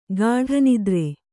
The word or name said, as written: ಗಾಢನಿದ್ರೆ